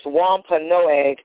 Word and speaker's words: Wampanoag: In 1621, the Plymouth colonists, and I'm going to try not to massacre this, Wampanoag, and it's spelled W-A-M-P-A-N-O-A-G.